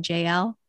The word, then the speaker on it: Jael: And she even shares the floor with another woman, and you'll know her pronunciation better than me, but J A E L. Is that J L? – Mm-hmm. Yeah